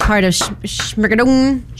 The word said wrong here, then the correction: Schmigadoon: Did I say it right? Yeah. One of the Schmigadoon. – Schmigadoon. I knew I didn't say it right.